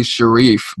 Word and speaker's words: Sherif: Yeah, it is technically Sherif, but I've gotten Sherif so often because it's just like closely, like the spelling is so close to the word Sherif.